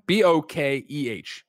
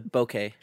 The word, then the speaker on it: bokeh: B O K E H.